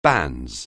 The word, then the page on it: banns: anglais